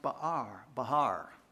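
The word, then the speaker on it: ba'ar: In the Hebrew, it's pronounced bahar, and it means foolish.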